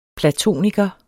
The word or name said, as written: platoniker